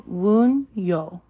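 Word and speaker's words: Wunjo: Phonetic value is W. Pronunciation Wunyo.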